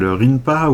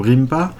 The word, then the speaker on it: Rinpa: enfin un courant artistique qu'on appelle Rinpa ou Rimpa avec ma prononciation à la française désolé.